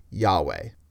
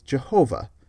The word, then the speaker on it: Yahweh: I need to actually bring up Yahweh. Because yes, that's apparently a decent guess as to how our new old friend the Tetragrammaton is pronounced. The letters more or less map to YHWH, then you sprinkle in some vowels. A less good way to guess at the pronunciation of the Tetragrammaton is Jehovah.